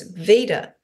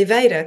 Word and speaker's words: Veda: And then over the years, I have had a lot to do with the Indian community, and they always call me VEDA because it's spelt the same as the Vedic scriptures.